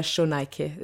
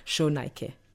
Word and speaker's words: Shonaike: The proper pronunciation is Iba Shonaike. There's no H in the spelling of my name, but you pronounce it with an A's, Shonaike.